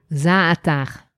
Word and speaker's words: za'atar: You say za'atar, I say za'atar. Okay, say it again. – Za'atar. – Za'atar, like a ch at the end? Oh, za'atar. Oh, you say it like the locals say it.